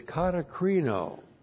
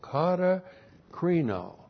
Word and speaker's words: katakrino: And the key word here is katakrino. K-A-T-A-K-R-I-N, long O, katakrino.